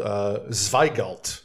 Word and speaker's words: Zweigelt: And a grape called Zweigelt.